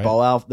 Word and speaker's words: Boao: There's a regional, we were talking about this the other day, it starts with the B, I can't pronounce it. There's a regional forum that's coming up. – Oh, the right? – Boao, the Boao forum that's coming up.